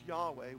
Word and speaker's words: Yahweh: it would be YHWH, which we pronounce Yahweh when we add the vowels. We put out an ah, an A, and an E.